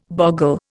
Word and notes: boggle: Indian

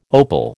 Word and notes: opal: American English

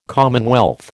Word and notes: commonwealth: American English

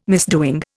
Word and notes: misdoing: Indian

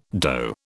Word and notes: dough: British English

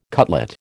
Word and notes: cutlet: American English

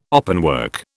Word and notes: openwork: British English

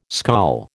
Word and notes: skol: American English

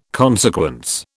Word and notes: consequence: British English

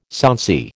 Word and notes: sonsy: American English

